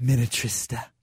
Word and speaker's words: Minnetrista: Minnetrista. I mean, that's not the most precious sounding town in America. My goodness. – What is the most precious sounding town in Minnesota? – Minnetrista.